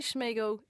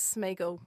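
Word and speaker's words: Sméagol: Schmeagol sounds better, but there's no reason you should be putting a shush in there. It's just SM, right? – I think maybe I'm channeling Gollum.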